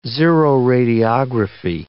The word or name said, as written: xeroradiography